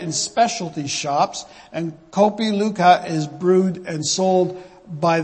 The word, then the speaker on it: Luwak: Kopi Luwak, sorry, that's how it's pronounced, Luwak,